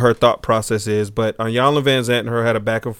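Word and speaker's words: Iyanla Vanzant: Williams has some things to say about she was with Ayanla Van Zant.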